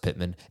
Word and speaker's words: Pittman: And I know it's pronounced Pittman.